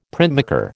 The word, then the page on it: printmaker: American English